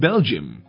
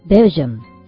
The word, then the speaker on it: Belgium: In Belgium. – あら、ベルギーは Belgium と発音するのね。